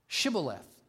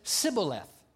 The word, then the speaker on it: Shibboleth: and the word was Shibboleth. Shibboleth. And that's the way the Gideonites pronounced it. But the Ephraimites could not pronounce that word. They said it Sibboleth. They couldn't make the SH sound.